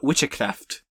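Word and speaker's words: wiccræft: There's a lot of that in Old English, like most C's that you see are pronounced ch if they're in the middle of the word. Like the Old English word for witchcraft is W-I-C-C-ash, so that's A-E put together, C-R-A-E-F-T. And it's witchcraft.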